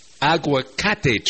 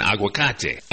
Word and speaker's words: aguacate: They're called aguacate trees in Spanish. And by the way, the first syllable in that word aguacate is agua, A-G-U-A, meaning water.